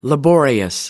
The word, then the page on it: laborious: American English